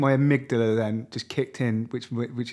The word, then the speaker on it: amygdala: How do you pronounce it? – I think it's amygdala. – Yeah, you're right.